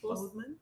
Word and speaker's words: Boseman: Boseman.